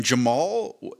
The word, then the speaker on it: Djamal: And Jamal, it was spelled with a D. It was a silent D. It was D-J-A-M-A-L.